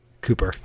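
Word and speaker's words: Cúper: The desperation to qualify was so great ahead of the Congo match that the Argentina-born Egypt coach Hector Cooper, what do you think is the Argentinian pronunciation of C-U-P-E-R? – Cooper. – Okay.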